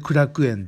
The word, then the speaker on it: 苦楽園: 苦しい楽しいその」ね、これで苦楽園って読むんですけどもねこの苦楽園口駅この駅から歩いて 3 分ぐらいのところにあるタイ料理屋さんプアンタイさん行ってきました。